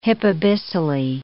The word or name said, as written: hypabyssally